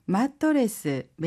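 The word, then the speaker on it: 마토레스 (マットレス): 마토레스, 마토레스, 매트리스입니다. – 파자마, 파자마. – 파자마, 파자마 혹은 잠옷이란 뜻인데요. 혹시 발음 팁 있을까요? – 하이, 마토레스는 초음이 들어가고 특히 모음 부분이 한국어 발음과 많이 다르니 주의해 주세요.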